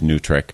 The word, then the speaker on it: NWTRCC: He's located in Milwaukee, Wisconsin, but the group NWTRCC, pronounced Nutric, is nationwide, and we've got people all over.